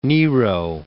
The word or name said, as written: Claudius I